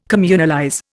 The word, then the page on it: communalize: Indian